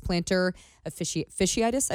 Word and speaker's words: plantar fasciitis: Of course, Jonathan India still out, which if you go back a few episodes, we talked to a doctor about his plantar fasciitis, I think yeah. – is how you say it. – Yes.